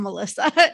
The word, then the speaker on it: Alyssa: or it's spelled differently than my name. So in my head, I'm like, no, I'm Alyssa, A-L-Y-S-S-A.